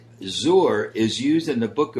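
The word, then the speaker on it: zor (זר): And very interestingly, Junie, the word for strange in Hebrew is a word that's pronounced zor. Perhaps if we were to spell it phonetically, it would be Z-O-O-R.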